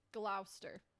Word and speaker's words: Gloucester: God, I even looked up how to say this, and I know I'm going to say it wrong. – Gloucester. – Gloucester? – Gloucester. Okay, I was like, yeah, I didn't remember that.